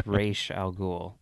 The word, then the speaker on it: Ra's al Ghul: If you want to pronounce it like Christopher Nolan does, but if you want to pronounce it the correct way, as established, I think prior to the animated series, but on the animated series, it's Ra's al Ghul.